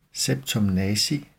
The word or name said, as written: septum nasi